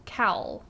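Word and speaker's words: caul: So how do you spell cowl? – It's... C-A-U-L. – So a cowl? – Cowl. – Cowl? – Well, it said pronounced cowl.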